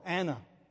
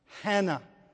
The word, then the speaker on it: Hannah: But we call her Anna. Actually, her name is Hannah. The rough breathing mark is in front of that, and the H is pronounced Hannah.